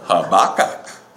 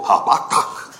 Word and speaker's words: Habakkuk: Habakkuk, Habakkuk. Emphasize the last syllable. Habakkuk.